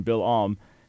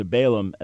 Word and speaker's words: Bil'am: And by the way, for folks listening that aren't familiar with the Hebrew pronunciation, Bil'am, as opposed to Balaam, as we call him in English. So that's who we're talking about, Balaam, or Bil'am in Hebrew.